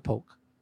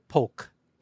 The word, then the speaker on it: Polk: Shout out to Doug Polk. – Doug Polar? – Polk. – Polk. – P-O-L-K. – And he says that he pronounces the L.